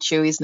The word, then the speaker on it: Tzuyu: See, I would have completely butchered Chewy's name because that's not what it looks like when it's spelled. – It's spelled T-Z-U-Y-U.